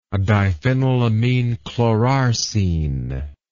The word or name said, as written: diphenylaminechlorarsine